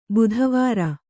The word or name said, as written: ಬುಧವಾರ